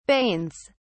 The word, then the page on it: banes: British English